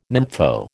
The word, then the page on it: nympho: American English